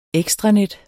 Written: ekstranet

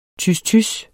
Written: tys

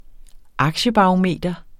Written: aktiebarometer

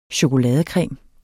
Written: CHOKOLADE

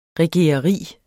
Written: regereri

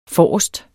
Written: forrest

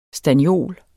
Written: STANNIOLET

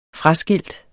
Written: fraskilt

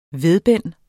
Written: VEDBENDEN

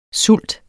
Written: sult

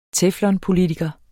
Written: teflon

